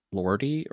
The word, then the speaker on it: Lordi: But no, this is spelled L-O-R-D-I. So I'm assuming it's Lordi.